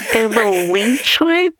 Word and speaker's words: Amlicites: Amlicites? – It looks like an antibiotic. – It has to rhyme with Nephites because Joseph Smith loved to rhyme.